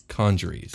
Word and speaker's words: congeries: Conjuries. – Yeah, that's how you say it.